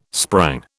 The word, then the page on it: sprang: British English